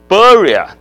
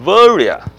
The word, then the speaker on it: Veria: 那这里要讲一下的就是那个名字现在已经不叫比里亚了，叫维利亚哈，就是 Berea 就变成 Veria，什么意思呢？这是希腊文的发音的变化。